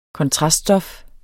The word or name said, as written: kontraststof